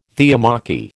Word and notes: theomachy: American English

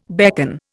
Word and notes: beckon: Indian

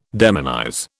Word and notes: demonize: British English

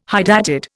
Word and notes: hydatid: Indian

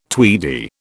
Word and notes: tweedy: British English

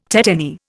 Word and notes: tetany: Indian

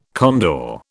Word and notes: condor: British English

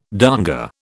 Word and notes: donga: British English

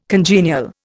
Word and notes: congenial: Indian

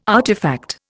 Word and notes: artefact: Indian

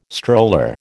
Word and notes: stroller: American English

